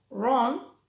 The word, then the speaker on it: wrong: También pasa cuando va seguida de R, como right o wrong.